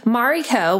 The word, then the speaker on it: Mariko: Mariko, which is my full name, is spelled M-A-R-I-K-O. It's a traditional Japanese name. I'm part Japanese. Mari, my nickname, is spelled M-A-R-R-I because my parents thought that if they stuck with M-A-R-I, people would say Mary or Marie.